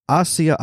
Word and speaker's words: Osceola: Osceola. – I'm never going to get that right. Osceola County Sheriff's Office. – Rhymes with cola.